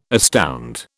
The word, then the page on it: astound: British English